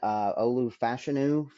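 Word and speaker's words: Olu Fashanu: I saw a Bleacher Report mock draft, and that had us still getting Olufashinu from Penn State, which I just recently learned that's how you actually pronounce his name.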